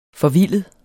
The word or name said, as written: forvildet